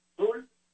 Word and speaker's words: Dool: 707-707 Dool Avenue. D-O-O-L. Dool. Se escribe Dool. Dool Avenue. 707 Dool. En inglés es Dool, le dicen, pero se escribe D-O-O-L de la noche, mañana viernes 5.